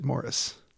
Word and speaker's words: Maurice: Although it's spelled the same, he pronounced it Morris.